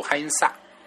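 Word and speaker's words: 海因 萨 (해인사): 就没有人了，啊，我就很迷茫，所以我就凑到司机的跟前儿，我就跟他说：“ 我说我要去海因寺，啊，海因寺的这个读音其实跟中文是差不多的，他们可能大概是读海因萨，就是只是最后一个四这个音啊略微不同。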